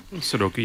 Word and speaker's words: Sudoku: Sudoku, yeah.